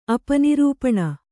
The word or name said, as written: ಅಪನಿರೂಪಣ